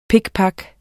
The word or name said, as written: pikpak